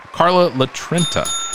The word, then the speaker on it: Carla Litrenta: We have Carla Litrenta.